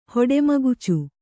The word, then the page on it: ಹೊಡಮಗುಚು: hoḍa magucu; Kannada